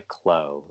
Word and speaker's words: Kllo: Back to You by Klo. That's spelled K-L-L-O.